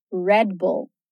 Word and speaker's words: Red Bull: A nossa próxima bebida, também famosíssima, que a brasileiramos aí como Red Bull, é pronunciada como Red Bull. red bull Red Bull. Duas palavras, duas sílabas. Aqui é simples. A gente já ouviu que o R é caipira, que as consoantes são sozinhas e que o U ali no meio vai soar como a.